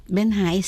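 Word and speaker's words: Bến Hải: Et dans votre récit « Ma terre empoisonnée », vous racontez bien ce moment du maquis où vous arrivez devant le fleuve Ben Hai, on prononce comment ? – Ben Hai, c'est bien.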